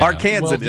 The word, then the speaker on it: Arkansan: Arkansan is the pronunciation.